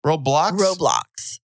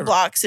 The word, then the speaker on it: Roblox: Roblox. Okay. – Yeah, well, Roblox.